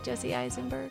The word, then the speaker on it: Jesse Eisenberg: Jesse Eisenberg? – Yeah.